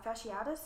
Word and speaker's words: fasciitis: All of the victim's organs are abscessed due to necrotizing facilititis. I think I'm saying that right. Uh, uh, fasciitis. – Fasci- fasciitis, that's right.